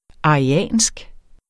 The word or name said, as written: ariansk